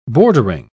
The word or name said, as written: bordering